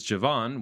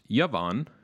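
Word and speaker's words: Yavan (יָוָן): Next is Javan, which is more properly pronounced Yavan, which is important so that you can hear its connection to the word Yonah, which is the English dove.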